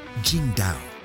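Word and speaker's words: Tsingtao: Oh, and it's pronounced Jingdao.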